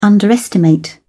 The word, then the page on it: underestimate: British English